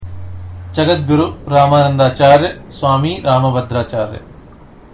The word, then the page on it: Jagadguru Rambhadracharya: Hindi